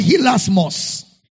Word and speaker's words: hilasmos: The word propitiation is the Greek word hilasmos. Hilasmos. Hilasmos is spelled as H I L A S M O S.